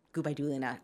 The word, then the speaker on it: Gubaidulina: Gubaidulina.